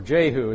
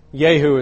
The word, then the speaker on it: Yehu: In the Black Obelisk, or Obelisk, in the British Museum, you have an inscription that it's an Assyrian inscription, the tribute of Yehu, or Jehu, as we say in America. Yehu is how it's pronounced in Hebrew, son of Kumri.